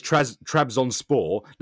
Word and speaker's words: Trabzonspor: The way that I do it is I break it up. So the club is Trabzonspor. Now, reading that, you're like, but actually, when you break it up phonetically, it's Trabzonspor. And so it just flows naturally.